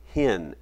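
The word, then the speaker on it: hen (ἕν): You know, the word for one there is a word, it's pronounced hen, H-E-N, hen.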